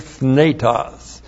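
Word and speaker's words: Thnetos (θνητός): T-H-N long E-T-O-S. Thnetos.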